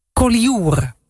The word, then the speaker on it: Collioure: Maar ze zit dus in Collioure.